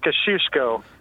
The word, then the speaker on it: Kosciusko: It's pronounced Kosciusko. – Kosciusko. – Kosciusko. – Gotcha. Cool. – Polish.